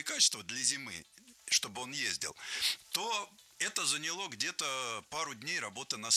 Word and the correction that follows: заняло: Нет, плохо, потому что не заняло, а заняло. Ударение не ставим на окончание ни в коем случае.